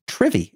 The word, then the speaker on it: Trivy: One of the most notable projects that come out of that is Trivy, if I'm pronouncing that correctly. Yeah, that's correct.